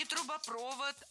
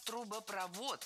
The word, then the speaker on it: трубопровод: Вот этот Кистоун, это, собственно, трубопровод, который идет из Канады. – Не трубопровод, а трубопровод. В сложных словах со второй частью «провод» ударение падает на последний слог.